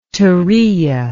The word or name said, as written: turiya